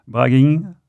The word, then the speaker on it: Bragging: Bragging, je sais pas si ça se prononce ainsi. Donc c'est extrait. – Si.